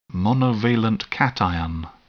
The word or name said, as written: monovalent cation